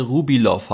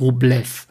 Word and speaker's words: Rublev: Ähm, ich stoße immer öfter auf die Frage, ob es Andrei Rublev oder Andrei Rubilov heißt.